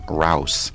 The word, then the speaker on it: Rouse: I'm going to pronounce his name, Rouse. It's R-O-U-S-E.